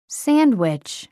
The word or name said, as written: sandwich